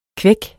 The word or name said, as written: kvæk